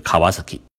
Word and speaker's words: 川崎: 第四款摩托车呢，叫川崎，日本念作是 Kawasaki。